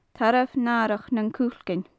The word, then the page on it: tarbh nathrach nan cuilcean: gaelic